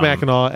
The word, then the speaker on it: Mackinac: And it's pronounced Mackinac, as many people, including Moltz, corrected me.